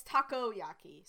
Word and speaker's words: takoyaki: According to Wikipedia page, it's takoyaki. So I mispronounced that.